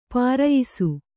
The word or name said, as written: ಪ್ರಾಯಶಃ